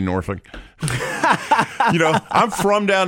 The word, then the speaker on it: Norfolk: I mean, look, I can count maybe on one hand how many times I've been to Norfolk, but, you know, I take pride in being able to say the name right.